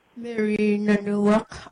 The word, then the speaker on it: Mary Nanuwak: Mary Nanuwak, M-A-R-Y-N-A-N-U-W-A-K.